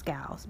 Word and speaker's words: galls: Now, my intention was to actually look up the pronunciation of this word, but I believe it's galls because it's pronounced like, it's spelled like ball, but it's with a G. So galls, G-A-L-L-S.